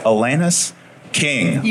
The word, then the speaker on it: Alanis King: And over here, special guest, and I'm going to say it right, Alanis King. – You got it.